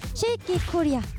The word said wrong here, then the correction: 쉐이킷 코리아: 여러분, 발음 실수하시면 안 돼요. 쉐이킷 코리아입니다. 네, 이 쉐이킷 코리아는요, 퓨전 국악 밴드 두 팀이 각자 연주를 하고 같이 합동 무대까지 여러분들께 들려드리는 네, 방송입니다.